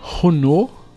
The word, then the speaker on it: Renault: E aí, assim, nesse acordo todo aqui, então a McLaren deve receber motores da Renault o ano que vem. Renault, eu falei certo? Não, a pronúncia francesa eu sei que tá certo porque eu fui numa exposição.